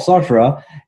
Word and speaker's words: Sartre: I kind of think, even as we are enmeshed in this big system, it reminds me a little bit of Sartre. And my southern tongue just butchered his beautiful name, Jean-Paul Sartre.